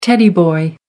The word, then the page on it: teddy boy: American English